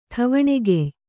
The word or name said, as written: ಠವಣೆಗೆಯ್